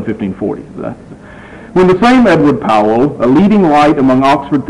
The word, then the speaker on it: Powell: I will continue to call him Edward Powell because that's the way we in America say it. It's probably pronounced Poole, but you can live with that. It's Powell in the card catalog.